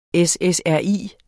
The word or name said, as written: SSRI